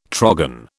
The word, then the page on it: trogon: British English